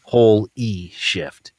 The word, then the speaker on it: Holyshift: The way it's pronounced it's whole, as in like a whole, not whole a half. – E. – Whole E shift.